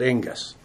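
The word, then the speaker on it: ingus (ἐγγύς): So at issue is how one understands the term ingus. Again, E-G-G-U-S, but for phonetically, the Greeks would have pronounced it ingus in this context.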